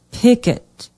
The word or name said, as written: pickett